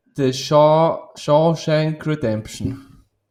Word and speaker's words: The Shawshank Redemption: Oder der Originaltitel war The Shawshank Redemption.